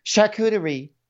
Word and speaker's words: charcuterie: And Evan is barbecuing, and Jackie is like, Evan's making all the food, so come enjoy some charcuterie. See, that's how you say it, Bravo people.